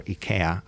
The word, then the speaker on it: IKEA: Moving on, another bit of news that really caught our attention this week was a statement by the head of sustainability at IKEA, or IKEA, as I learned, is actually pronounced officially, saying that we have reached peak consumption of things like the furniture that they manufacture.